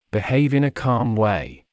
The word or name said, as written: behave in a calm way